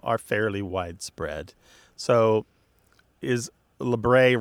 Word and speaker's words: labret: Labret. Yeah. Stress on the first syllable.